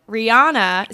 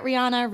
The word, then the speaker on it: Rihanna: This morning, Rihanna. Is that how you say it? Rihanna. – Rihanna. – I think actually is Rihanna. – Oh. Rihanna. – I've always thought it was Rihanna. – I think everybody says it that way, but she pronounces it Rihanna. – When she's in interviews and she is asked, she says Rihanna.